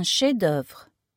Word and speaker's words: chef-d'œuvre: Un chef-d'œuvre. Masterpiece. Note the F is silent.